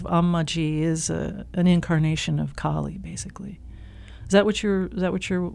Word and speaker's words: Kali: So Kali is with a long A.